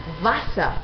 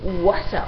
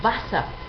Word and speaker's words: Wasser: La W en alemán se pronuncia como una V siempre. Wasser, nada de Wasser o algo parecido, sino Wasser, agua.